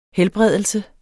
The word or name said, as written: helbredelse